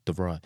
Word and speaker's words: de Vrij: De Vrij.